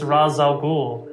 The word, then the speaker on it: Ra's al Ghul: The problem is that it's an Arabic name, and the pronunciation in Arabic is Ra's al Ghul.